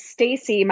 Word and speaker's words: Staci: So it's Stacy. My parents got creative in the 70s and they decided to spell it S-T-A-C-I.